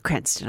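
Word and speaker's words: Cranstoun: It's spelt C-R-A-N-S-T-O-U-N. I feel like the U is superfluous. – You're better at that than me. – well, it's too much. Cranston, I'm going to say.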